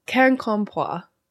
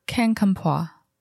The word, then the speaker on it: Quincampoix: Quincampoix. Like can, like the Q is like a can sign sound. So Quincampoix. – Quincampoix.